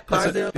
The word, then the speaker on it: Clydesdale: Yeah, Clydesdale.